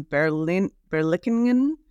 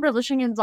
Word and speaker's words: Berlichingen: Your introduction, you discuss the autobiography of a German knight, Gutz von Berlichingen. I should be better at that. Yeah, there we are. That was the Dutch pronunciation you just heard.